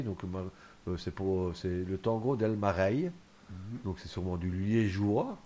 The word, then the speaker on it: Mareye: Donc ça s'écrit M-A-R-E-Y-E, donc c'est Mareille.